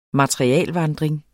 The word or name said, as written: materialevandring